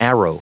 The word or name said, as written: arrow